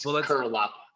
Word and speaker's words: Curelop: No, but I'd say like 5% of people actually say it right. It's Curelop.